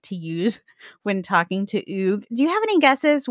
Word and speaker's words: Hugues: No, it's Hugues. No, yeah. Hugues.